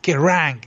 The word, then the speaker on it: Kerrang: ¿Cómo se dice, señor? – Sí, es Kerrang.